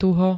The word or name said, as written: tuho